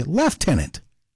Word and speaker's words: Lieutenant: It's spelled C-O-L-O-N-E-L, but pronounced Colonel. And right behind it is Lieutenant. In Canada, we say Lieutenant. There's no F there.